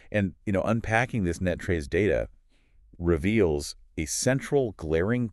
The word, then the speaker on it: NTREIS: is pronounced locally as NETTRES.